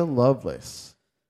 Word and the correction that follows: Lovelace: Lovelace? – Lovelace, right.